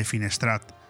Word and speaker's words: Finestrat: en la Casa de Cultura de Finestrat.